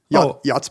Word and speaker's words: yacht: It's like it's a portmanteau of yacht and otter. – I guess you can't say yachtsman anymore. Yachts people? – Yachtspersons. – Yeah, the yachtspersons. Only word with a silent CH, by the way.